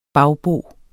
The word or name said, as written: bagbo